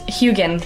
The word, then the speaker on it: Heughan: Am I pronouncing him correctly? – No one ever does. Heughan. – Heughan.